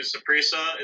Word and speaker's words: Saprissa: returning back home to to Saprissa.